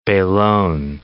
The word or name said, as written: belon